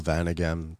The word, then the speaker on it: Vaneghem: When we talk about everyday life, because, of course, there's Raoul Vaneghem. Did I pronounce that correctly? – It's fine. – Vaneghem.